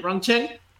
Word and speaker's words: Rongcheng: The following game will be against Chengdu Rongcheng, I just did it in Spanish pronunciation, Rongcheng, on November 8th, so 3 days later.